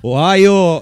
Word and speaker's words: Ohio: Lo Stato americano di cui ha un nome strano, scritta con la O, ma si legge a Ohio, adotta il sistema metrico di misura che rappresenta ormai uno standard mondiale.